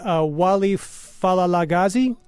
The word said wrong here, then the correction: Wali Palaligazi: It's close. Wali Palaligazi.